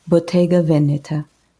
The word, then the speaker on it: Bottega Veneta: The second one is Italian, and it's Bottega Veneta.